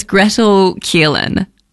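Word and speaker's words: Gretel Killeen: First of all, it's Gretel Keelan, I believe. Am I saying that right? – Yeah.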